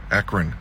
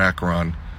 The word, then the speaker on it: Akron: Akron, not Akron.